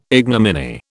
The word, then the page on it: ignominy: British English